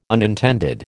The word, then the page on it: unintended: American English